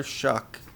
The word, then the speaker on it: Shough: I learned that that is the pronunciation. Doesn't make any sense to me when it's spelled Shough, but Tyler Shuck coming over from Oregon.